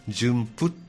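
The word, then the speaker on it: 順峰: 順布順布順番の順に、峰ですね大峰の峰、はい、順布、うん、と読むらしいん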